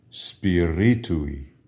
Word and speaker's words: Spiritui: Spiritui. Spiritui. Notice the accent is on the I following the R. Ri, spiritui, and the final vowel or letter of this form of spiritus is an I, spiritui.